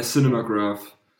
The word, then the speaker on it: Cinemagraph: Ja, Cinemagraph.